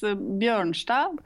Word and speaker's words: Bjornstad: It's Bjornstad in Norwegian. – Bjornstad. – So it's like the O is instead of Ø, which is a, you know, special Norwegian letter that you don't have in your alphabet.